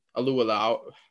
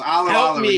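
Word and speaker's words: Alualu: You really butchered it. I told you how to say it in the beginning of the show and you completely butchered it. I told you it was Alualau.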